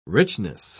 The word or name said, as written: richness